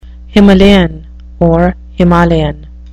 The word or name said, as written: Himalayan